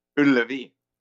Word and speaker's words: Ullevi: How do you say the stadium? – Ullevi. – Ullevi Stadium in our beloved Sweden.